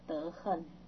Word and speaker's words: 德行: 探得接着陀罗尼去，只是探得金取不退转，集成两义，就是名位，名它的位阶跟它的德恨。德行不能念恨，小学我们都学恨，就后来说把这个恨的读音去掉以后常常不会念。你们学恨还学行？